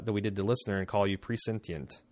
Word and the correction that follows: prescient: How do you say that word? – Prescient.